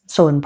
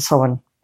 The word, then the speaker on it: Samhain: And on Saturday night, when we went to the Samhain party, Soin party, I say it like the publisher now. I should go back to Soin, Soin party.